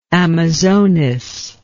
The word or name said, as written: amazonis